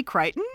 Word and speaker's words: Crichton: Is it really Crichton? – It is Crichton. I know. Crichton. – I've been pronouncing it Crichton my whole life.